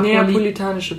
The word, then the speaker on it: neapolitanische: Zur napolitanischen Pizza. Mhm. Könnt ihr das aussprechen? – Ich kann es nicht aussprechen. Neapolitanische Pizza. – Oh, ich wollte Napoleon sagen. – Ja, ich irgendwie auch immer. – Das kommt ja aus Neapel.